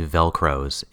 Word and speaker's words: Velcrows: They've been picked up hitchhiking by Velcorus Widley in his Mad Max-style bus. – His name is actually Velcros. It's spelled V-E-L-C-R-O-W-S.